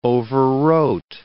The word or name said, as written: overwrote